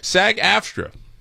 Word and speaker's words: SAG-AFTRA: SAG-AFTRA. Did I say that right? Yeah, I did say. Don't stop laughing at me. SAG-AFTRA.